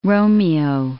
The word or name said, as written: Romeo